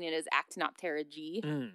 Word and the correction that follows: Actinopterygii: But I actually looked at a phonetic outline of it. Actinopterygii.